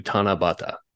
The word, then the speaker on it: Tanabata: And then somehow when you put them together, it comes to Tanabata.